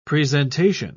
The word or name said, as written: presentation